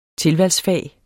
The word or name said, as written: tilvalgsfag